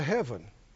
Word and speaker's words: HEVVN: And so Perry overheard on the radio that they'd cleared me direct to heaven. – It's an intersection. It's spelled H-E-V-V-N. And that's how they pronounce it, heaven.